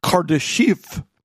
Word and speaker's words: Kardashev: And it's pronounced Kardashev.